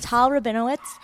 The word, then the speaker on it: Tal Rabinowitz: Tal Rabinowitz. Hello. Did I say it right? – Yeah, absolutely.